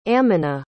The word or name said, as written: aminah